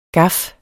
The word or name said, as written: Gaffen